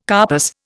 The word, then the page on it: carpus: Indian